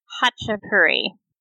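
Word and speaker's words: khachapuri: Well, it starts with a K, but this blog here says it's pronounced Hachapuri.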